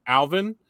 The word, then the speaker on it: Allvin: Patrick Allvin, Allvin as the new GM. Which I love this. His name is spelled A L L V I N. I mean, that's like all win or all in.